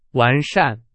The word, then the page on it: 完善: Chinese